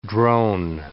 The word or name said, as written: drone